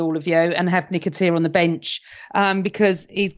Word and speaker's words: Nketiah: It's Nketiah, by the way.